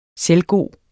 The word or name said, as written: selvgod